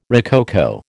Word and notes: rococo: American English

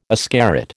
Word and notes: ascarid: American English

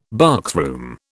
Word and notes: boxroom: British English

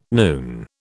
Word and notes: noon: British English

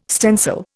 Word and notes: stencil: Indian

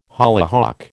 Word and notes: hollyhock: American English

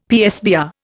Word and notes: PSBR: Indian

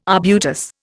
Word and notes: arbutus: Indian

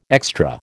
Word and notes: extra: American English

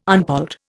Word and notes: unpolled: Indian

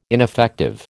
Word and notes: ineffective: American English

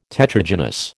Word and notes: Tetragynous: American English

